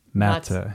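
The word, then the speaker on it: Maté: It's it's Hungarian. He told me you put the emphasis on the first syllable. Gabor Mate.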